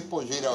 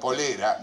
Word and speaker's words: Pollera: Adiviná bajo qué pollera va a estar. – Bien. – En realidad se pronuncia polera, no pollera, se escribe pollera Igual Polera queda en el rubro textil.